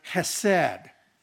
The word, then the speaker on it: hesed: The Hebrew word is the word hesed. Hesed. It actually begins with a ch, if we were to put it into English terms.